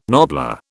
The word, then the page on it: nobbler: British English